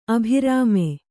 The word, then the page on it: ಅಭಿರಾಮ: abhirāma